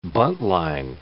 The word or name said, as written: buntline